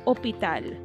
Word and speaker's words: hôpital: If you look at the H that is oh so important in English, the H that makes hospital, hospital, turns into the H aspirat, the silent H in French, and hospital is hôpital.